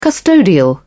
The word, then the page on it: custodial: British English